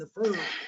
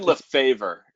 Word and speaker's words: Lefevre: Edwin Lefevre is actually how it's pronounced.